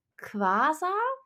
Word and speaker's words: Quasar: Und zwar gibt es den Begriff Quasar. Ist das richtig ausgesprochen? Mhm. Ähm, ja, das ist korrekt.